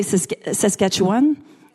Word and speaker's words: Saskatchewan: Sorry, in French you say Saskatchewan. That's how you say it in French.